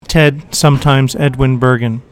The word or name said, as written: Edwin Bergin